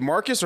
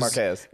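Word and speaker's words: Marques: Is it Marcus or Marquez? – Marquez.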